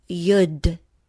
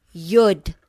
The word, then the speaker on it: Yod: This letter is Yud. It is spelled Y-O-D. Wow, what a letter. Positionally, in the Hebrew alphabet, is the 10th letter. Yud. I'll say it again. Yud.